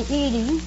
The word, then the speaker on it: Edie: No, do they call you Edith or Edith? – They usually call me Edie. – Edie? – I'd like to have you call me Edie, if you don't mind.